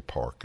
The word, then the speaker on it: Park: Yeah, it's pronounced Park in Korean, but I should say Park, you're right, to make it clear, in English.